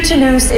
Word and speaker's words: Toulouse: Your Lufthansa Starlines flight LH2222 to Toulouse is now ready for boarding at departure gate K1. – Ik kan het blijven. – Wel een goede klemtoon gelegd, hè? – Ja, hè?